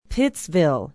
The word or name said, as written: pittsville